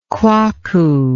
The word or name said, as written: quaco